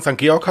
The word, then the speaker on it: St. Georg: Ich kann dir aber sagen, St. Georg hast du richtig ausgesprochen.